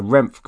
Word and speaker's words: REMF: So Renf, guys, is spelt R-E-M-F, which stands for rear echelon motherfucker.